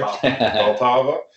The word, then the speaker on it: Vltava: It's situated on the Vltava River. I never say that right. Vltava.